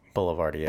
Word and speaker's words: Boulevardier: And my question is it pronounced Boulevardier or Boulevardier? – Boulevardier. – Boulevardier. – Okay, yeah. I do like a good Boulevardier.